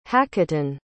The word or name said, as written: haketon